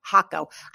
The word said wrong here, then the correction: Hakko: It's Kyowa, new word, Hakko.